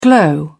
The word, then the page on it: glow: British English